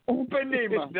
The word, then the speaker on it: Ipanema: It's called The Girl from Empanina. Did I say that right? – Upanema. – Did I say that? – Upanema. The girl from Upanema.